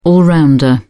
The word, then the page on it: all rounder: British English